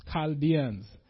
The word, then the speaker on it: Chaldeans: For lo, I raise up the Chaldeans, I looked up how this is pronounced, Chaldeans,